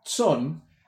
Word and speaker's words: Tson: Ese señor se llama Joseph Tson. Ayer vi que su libro cuesta alrededor de 150 pesos, así que si quieren buscarlo, se escribe J-O-S-E-F, Joseph, y T-S-O-N es el apellido, Tson.